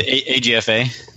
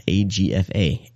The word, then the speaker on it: AGFA: Oh, AGFA. – Yeah, AGFA. AGFA.